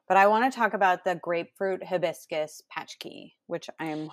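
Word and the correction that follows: pączki: and I learned how to pronounce this word because I butcher it. it's actually punchki.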